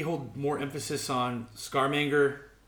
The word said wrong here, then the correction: Scaramanga: uh, Scarmanga. How do you say it? Scaramanga. Scaramanga.